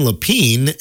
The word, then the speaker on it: Lepien: If you want to check him out, it's Dan Lapine, and Lapine is spelled L-E-P-I-E-N.